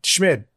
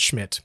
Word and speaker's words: Schmid: Schmid. Dylan Schmid. It's spelled S-C-H-M-I-D, not Schmidt, by the way.